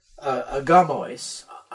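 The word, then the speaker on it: agamois (ἀγάμοις): Now, the word for unmarried is agamois, accent on the ga there.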